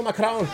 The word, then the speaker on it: Macron: You're getting bitch slap like Emmanuel Macron. Did you just roll your R in a French name? I did.